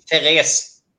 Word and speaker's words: Therese: That's Swedish. Therese. – Therese.